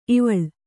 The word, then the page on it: ಇವಳ್: ivaḷ